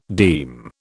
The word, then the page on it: deem: British English